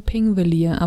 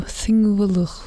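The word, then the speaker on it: Þingvellir: P-I-N-G-V-E-L-L-I-R. Wir würden es aussprechen Pingvelier, aber das wird so Thingvelir. Irgendwie ganz komisch ausgesprochen, mhm.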